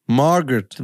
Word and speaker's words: Margot: Genau, wie wird die Vorname ausgesprochen? Margot? – Margot. – Margot Robbie, okay.